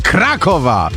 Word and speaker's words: Krakowa: Z Krakowa! Tak zaakcentowałaś, jak się powinna akcentować w Małopolsce, prawda? – Tak jest, musiałam wyjść na pole.